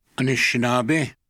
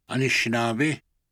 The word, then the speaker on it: Anishinaabe: Anishinaabe. Anishinaabe.